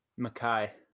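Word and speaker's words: Mekhi: How do you say that name? – Mackay. – That's what I said. – Becton. Yeah, Becton's right. You guys nailed that phonetically.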